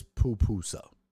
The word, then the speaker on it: pupusa: Yeah, I was saying papusa, but it is pupusa.